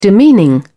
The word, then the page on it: demeaning: British English